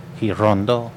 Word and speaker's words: Girondo: Oliverio Girondo. – Say it again. – Girondo. – Girondo? – Yeah, you got it.